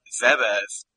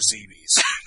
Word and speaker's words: Zebes: Zebes has an official pronunciation. I still prefer Zebes.